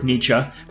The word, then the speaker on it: Nietzsche: In this episode, we start off talking about the Entrepreneur's Weekly Nietzsche and how the philosophy of Friedrich Nietzsche, pronounced Nietzsche and not Nietzsche as I stand corrected, applies to the world of entrepreneurship.